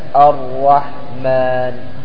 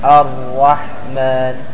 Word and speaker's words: الرحمن: فما يقال أر أر عندما خاصة يكون مشدد مثل الرحمن، بل تحاول بقدر المستطاع أنك تجتنب هذه الصفة حيث تنطقها راء واحدة الرحمن. انطق معي الرحمن.